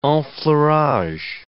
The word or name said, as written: enfleurage